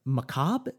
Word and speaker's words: macabre: And they're always kind of macabre. Is that the way you pronounce it? – Yeah.